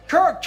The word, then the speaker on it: Kirk: So this is for the best Christian actor who got his start in Growing Pains and whose first name rhymes with Dirk. It's a fiercely competitive category. But this year, the award goes to Kirk Cameron.